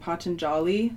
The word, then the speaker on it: Patanjali: Because it first just started as a meditative practice with the, what's his name, Patanjali.